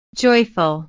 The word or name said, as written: joyful